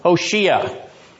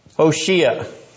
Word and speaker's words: Hoshea: Hoshea. – Hoshea. It's actually spelled the same way Hosea is in Hebrew, but we give the English Hoshea to it to distinguish it from the prophet so there's no confusion.